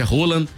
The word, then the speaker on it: Haaland: que na verdade é Haaland, pela pronúncia que ele mesmo falou.